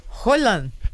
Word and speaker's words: Haaland: Roland.